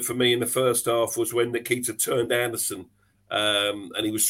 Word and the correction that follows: Nketiah: Nketiah, not Nikita. Nikita's Elton John's song.